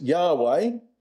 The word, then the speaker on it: Yahweh: As we'll see here, that's the Hebrew word, and it's pronounced Yahweh, and it's the divine name, and its meaning is, I will be who I will be.